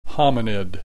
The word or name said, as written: hominid